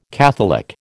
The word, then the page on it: catholic: American English